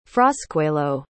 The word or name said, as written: frascuelo